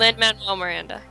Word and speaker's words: Lin-Manuel Miranda: I like the interview footage with, how do you pronounce the dude's name? Because I Which one? – Lin-Manuel, is that, did I get it right? – Lin-Manuel Miranda. – Yes.